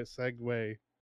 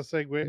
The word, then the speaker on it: segue: So it's it's Italian. Oh. For it follows. So that's why yeah. – it's spelled that way. Segway. I just never thought about that. That's that's there's a segue.